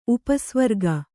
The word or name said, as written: ಉಪಸ್ವರ್ಗ